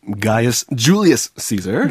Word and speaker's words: Gaius Julius Caesar: 가이우스 율리우스 카이사르. 영어로는 어떻게 발음되나요? – Gaius Julius Caesar.